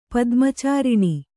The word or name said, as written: ಪದ್ಮಚಾರಿಣಿ